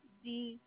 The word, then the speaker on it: Swayze: Yep, it's Swayze. – You said Swayzey? – Like Patrick Swayze, so Swayze. Swayze.